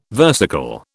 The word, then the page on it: versicle: British English